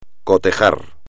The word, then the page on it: cotejar: espagnol